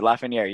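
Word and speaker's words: Lafrenière: No, but that is how you pronounce it, Lefreniere.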